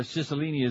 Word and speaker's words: Cicilline: The 41-year-old son of the state's best-known organized crime lawyer, Cicilline is the way you pronounce it in Italian.